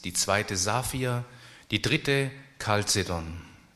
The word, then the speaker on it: Chalcedon: Also das CH wird eigentlich im Griechischen wie ein feines C ausgesprochen, nicht ch, wie die Schweizer das CH aussprechen, ja, sondern Chalcedon. Und da steckt das Wort Kupfer drin.